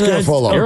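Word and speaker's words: Garafolo: It doesn't rhyme with Garoppolo. It's Garofolo.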